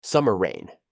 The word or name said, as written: summer rain